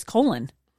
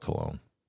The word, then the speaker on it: Colon: I said, how do you pronounce colon, Michigan? And it gave every city in Michigan with a pronunciation guide. Do you want to bet me right now? – I'm going to call it cologne.